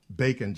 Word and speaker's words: Bacon: Is that Bacon? Just like the meat.